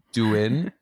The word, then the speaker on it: Douyin: It's called, and I'm going to butcher this, it's called Douyin. It's spelled D-O-U-Y-I-N.